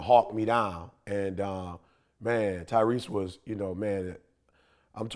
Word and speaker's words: Tyrese: I'm going to probably say, you know, and maybe it was a couple, I don't know the order, but Tyree. Am I saying that right, Tyree? – Tyrese Gibson. Yeah.